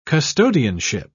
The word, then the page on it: custodianship: British English